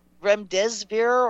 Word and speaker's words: remdesivir: And you know the remdesivir or something that Fauci is recommending? Mm-hmm. How do you say that? – Remdesivir, I believe.